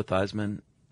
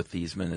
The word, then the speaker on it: Theismann: By the way, you know, Joe Theismann. I don't know if this is true. Supposedly his name was pronounced Theismann until college, really? – And he changed it to rhyme with Heisman in a push for the trophy.